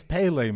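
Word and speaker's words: Pelé: It's Pele, man. – The soccer player. – We're not going down this road again. We're going to have to restart. – I say it's Pele.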